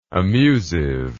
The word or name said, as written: amusiveness